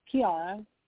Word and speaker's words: Kiara: Kiara.